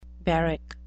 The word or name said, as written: barrack